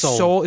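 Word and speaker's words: Soule: I mean, it's not my favorite book on the stands right now, but I think that what Charles, I think his name is Soul. It's how you say it, Soul,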